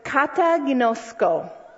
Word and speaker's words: kataginosko: But the word for condemnation you saw on day four of your homework is the word kataginosko. Okay, if you want to write it down, here's how it's spelled. K-A-T-A-G-I-N-O-S-K-O.